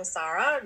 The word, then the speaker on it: Sara: So I am Sara, or Sara really is my how to pronounce my name.